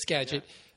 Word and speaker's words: Skagit: I always say Skagit because it looks like that's how you should pronounce it. – No, you're correct. – Right, it's Skagit.